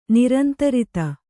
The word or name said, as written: ನಿರಂತರಿತ